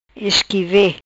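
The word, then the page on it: Isqueve: Patuá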